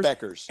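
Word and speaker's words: Bueckers: Is that how it's pronounced, Beckers? – Beckers, yeah.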